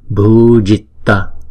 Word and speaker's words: Bhujitta: Chúng ta nhớ là chữ J này nè, là chúng ta đọc là J, J, J, chứ không phải là chờ. Giờ, Bhujitta, Bhujitta, Krittaya, Krittaya.